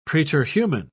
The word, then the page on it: preterhuman: British English